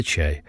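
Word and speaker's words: «ча» (茶): Консультация стоит от 20 до 100 долларов, а 100 граммов лечебного чая с разными составляющими частями – до 150 долларов. Китайцы, а затем голландцы, торговали чаем из Кантона, где название этого продукта произносится как «ча».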